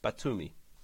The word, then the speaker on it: Batumi: Beautiful pronunciation again, and the Black Sea resort town of Batumi.